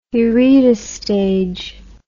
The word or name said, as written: uredostage